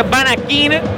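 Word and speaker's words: Vanaken: Banaquén se escribe, se pronuncia Banaquín, pero cambio de última hora.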